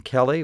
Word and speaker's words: Kelly: Our address is my name, Kevin Kelly, which is K-E-L-L-Y, Illinois Public Media, 300 North Goodwin Avenue, that's spelled G-O-O-D-W-I-N, G-O-O-D-W-I-N, 300 North Goodwin Avenue, Urbana, Illinois, Area 61801, or rather, zip code 61801.